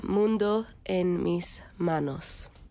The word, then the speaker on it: Mundo en mis manos: So it's called Mundo en mis manos.